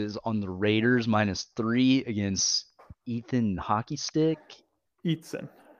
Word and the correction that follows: Easton: Am I saying that right? Eatson. Eatson. Eatson? – Yeah. Easton? Easton, thank you.